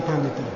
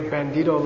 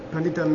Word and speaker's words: Pandita: He passes it on to his nephew, who's the Sakya Pandita. Say Sakya? Pandita. In Mongolia, they can't pronounce Pandita properly, and Rinpoche and I once were reading a Mongolian thing, and described the great Bandito Lama.